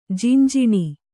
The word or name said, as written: ಜಂಜಣ